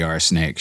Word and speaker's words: RSnake: And you might check out other episodes of the Arsenake Show, spelled R-S-N-A-K-E, although pronounced more like arsenic, I guess, at least to my ear.